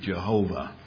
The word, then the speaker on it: Jehovah: And then what we did is we took the vowel sounds from Adonai and we put the ah under the Y, so Yahowah. And so Yahowah, Yahowah, Jehovah is where that word came from.